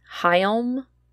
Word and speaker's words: Hjelm: On January 9th, Stephen Hyelm, I think is how you say that, H-J-E-L-M.